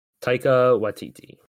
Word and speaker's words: Taika Waititi: you and to keep Taika Waititi. Is that how you say his name? – Taika Waititi.